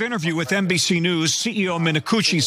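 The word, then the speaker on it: Minicucci: Alaska Airlines CEO Ben Minakuchi, that is his name, that is how you pronounce it, we did double check, is furious with Boeing, which manufactures all of the planes in Alaska Airlines' fleet.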